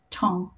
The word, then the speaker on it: Tung: And last name is Tong, T-U-N-G.